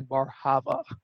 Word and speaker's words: Bar Hava: So, Dan Bar Hava.